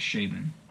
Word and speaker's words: Chabon: I'm never sure how to pronounce his name. – I think it's Chabon. I think I looked it up, and it's that.